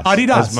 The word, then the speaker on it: Adidas: Regarding Adidas, your mother was actually pronouncing the name correctly. It comes from the name of the founder, Adolf Dassler, whose nickname was Adi.